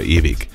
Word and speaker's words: ewig: Sicherlich in Süddeutschland, Österreich und der Schweiz sagen die Leute auf der Straße König oder ewig. In der Standardaussprache ist dies aber ein absoluter Fehler, außer wenn es sich um ein Mundartgespräch handelt. Das IG am Wortende muss immer ICH sein.